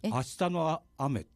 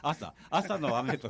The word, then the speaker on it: 朝: これ正しくはね、明日の雨って言うんですよ。朝のこと、明日、と読むんです。それ明日の雨、文芸的にね。 – どこだ朝の雨と書いて、はい、明日の雨と聞く。